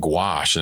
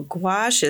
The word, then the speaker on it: gouache: Well, gouache is spelled G-O-U-A-C-H-E. – Okay. – Which is very confusing. – Yeah. – Yeah. So if you look at A-C-H. Gouache. E. Oh, you got to put the E on the end, too.